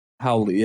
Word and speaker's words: Hawley: H-A-W-L-E-Y. How do you pronounce it? – Howley, yeah. – Howley. – Holly or Howley? – Howley.